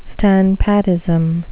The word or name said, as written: standpatism